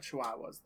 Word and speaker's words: Chihuahua: I'm not a fan of Chihuahuas, though. I'm pretty. I guess that's. – It's pronounced spiteful. Chihuahua.